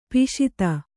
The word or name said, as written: ಪೋಷಿತೆ